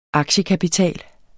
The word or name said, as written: AKTIEKAPITAL